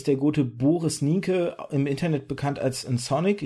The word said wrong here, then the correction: N-Sonic: Ich hoffe, das ist richtig ausgesprochen. Hallo. – Moin, ja, äh, N-Sonic kann man ganz einfach sagen. – Ach, Ensonic, war, okay.